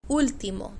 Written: ultimo

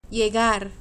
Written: llegar

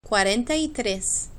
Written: cuarenta y tres

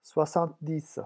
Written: soixante-dix

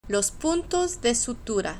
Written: los puntos de sutura

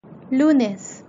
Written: lunes

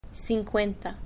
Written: cincuenta

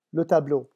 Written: le tableau

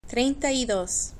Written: trienta y dos

